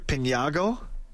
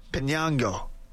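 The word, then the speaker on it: Piñango: I can't even pronounce this guy's name. Johendrick Pinyango? Pinyango, I believe.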